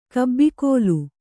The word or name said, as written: ಕಬ್ಬಿಕೋಲು